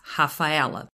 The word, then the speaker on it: Rafaela: I'm from Brazil, and we pronounce initial R's as a hard H, so my official name is Rafaela.